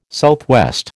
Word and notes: SW: American English

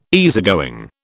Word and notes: easygoing: British English

